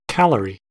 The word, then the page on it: calorie: American English